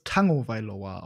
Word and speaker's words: Tagovailoa: Man spricht den tatsächlich, das kommt aus dem Hawaiianischen, spricht man das Tagovailoa aus.